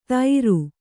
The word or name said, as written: ತೈರು